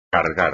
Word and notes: cargar: espagnol

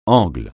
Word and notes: angle: francais

